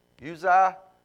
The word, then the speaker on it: Uzziah: Am I saying that right? Uzziah? Okay, just making sure because Uzziah was...